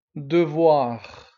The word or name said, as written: devoir